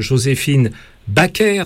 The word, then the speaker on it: Joséphine Baker: Vous aviez présenté votre livre sur le parcours de Joséphine Baker, hein, je prononce bien cette fois-ci. – Absolument, oui.